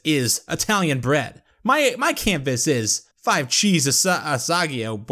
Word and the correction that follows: Asiago: whatever the fuck." – Asiago. – My canvas. Yeah, that's the word I butchered mercilessly.